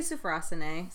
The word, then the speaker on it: sophrosyne: Next week, we are going to be looking at temperance and sophrosyne. – Close. – Okay. How do you say that? – I say sophrosyne.